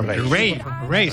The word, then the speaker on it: Raye: Ray.